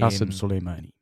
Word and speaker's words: Soleimani: Qasem Soleimani exactly.